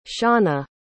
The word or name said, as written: shawnna